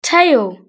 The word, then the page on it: tail: British English